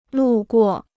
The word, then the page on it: 路过: lù guò